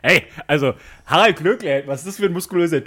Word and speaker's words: Glöckler: Harald Glöckler, so schwer ist es jetzt nicht. Glöckler.